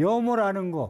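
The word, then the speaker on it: 염오: 싫어할 염자, 악할 학자인데 오라고 읽는다, 싫어할 오자로 읽는다 했어요. 염호라는 거, 뉴욕조, 기법 뉴욕조는 여러분 들어봐서 아는데, 염호라는 얘기는 좀 잘 안 들어봤을 거예요.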